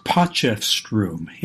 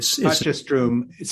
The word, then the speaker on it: Potchefstroom: Well, the way I say it is Potchefstroom. – Potchefstroom, South Africa, which I guess is an Afrikaans word, or is that a Zulu word, or what is that? – I really don't know.